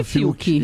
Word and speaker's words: Fiuk: É Fiuk ou Fiuk? Como é que fala o nome dele? – É assim que eu chamo ele de Fiuk.